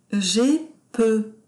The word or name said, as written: jai peu